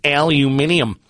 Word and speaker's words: aluminium: $5 aluminium pints of Miller Lite, because the British pronunciation of aluminum is more fun. Aluminium.